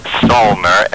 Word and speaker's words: Salner: The name again is Rebecca Salner, S-A-L-N-E-R.